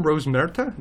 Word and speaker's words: Rosmerta: Madame Rosmerta, am I pronouncing that correctly? – Mm-hmm.